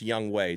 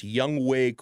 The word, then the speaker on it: Younghoe: I found this out today that it's pronounced Younghoe. It's spelled Younghoe. And I was saying that all throughout the postgame yesterday, so excuse me. But apparently his name is really pronounced Younghoe Koo.